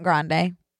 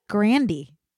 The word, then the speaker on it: Grande: No, it's not Grande. – Grand? – No. Grandy.